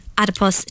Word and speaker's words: adipose: John, you were telling us about having more brown fat, more brown adipose tissue, if I'm pronouncing that right. – That's very good. That's the right way.